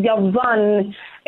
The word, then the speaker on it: Yavan: Greek actually means, or is actually pronouncing Yavan, In Hebrew.